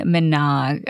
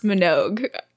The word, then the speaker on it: Minogue: For you, we picked Can't Get You Out of My Head by Kylie Minogue, okay? – I will say it is pronounced Minogue.